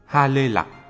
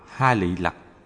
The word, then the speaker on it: Ha Lê Lạc: Ha Lê Lạc, phiên âm từ Phạn ngữ Haritaki, cũng đọc là Ha Lị Lạc, là một loại cây có trái, rễ, thân, cành, lá, hoa và hạt đều có vị đắng.